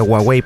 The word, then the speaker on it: Huawei: Y se pronuncia Huawei, por ciento, no Huawei. Se los digo porque trabajo con con este con personas de China y ellos no le dicen Huawei, ellos dicen Huawei.